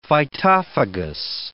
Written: phytophagous

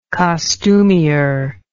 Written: costumier

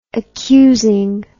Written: accusing

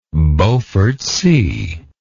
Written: beaufort sea

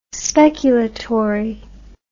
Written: speculatory

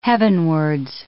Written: heavenwards

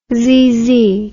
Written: xyzzy

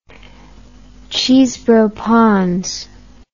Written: Chesebrough-Pond's

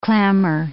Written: clamor

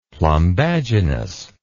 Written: plumbaginous

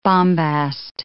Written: bombast

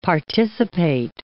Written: participate

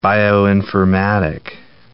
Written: bioinformatic

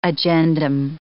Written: agendum

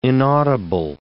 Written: inaudible

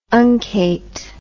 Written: uncate

